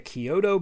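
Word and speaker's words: Chiodo: It is by the Kyoto Brothers. That is how you pronounce that. I looked it up. I was not sure before.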